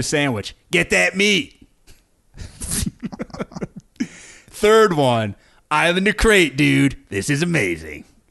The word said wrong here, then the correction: Crete: Wait, did he say crate or did he say Crete? – He said Crete. – Okay. – That was me mispronouncing it. I'm the idiot.